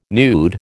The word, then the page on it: nude: American English